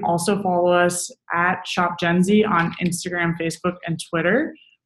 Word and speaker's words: Jenzy: It's spelled J-E-N-Z-Y, Gen Z.